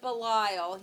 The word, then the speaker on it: Belial: B-E-L-I-A-L, or Belial, or Belial. And in the Bible, they put the emphasis on the first thing, but...